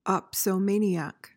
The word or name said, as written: opsomaniac